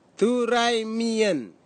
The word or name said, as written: turaimien